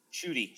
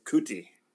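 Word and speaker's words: Ncuti: Sorry, how do you pronounce his first name? – Shudi. – Shudi, okay. – Shudi. – Yeah, because I was going Kuti. – Nope, Shudi. I had awesome.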